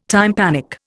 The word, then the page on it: tympanic: Indian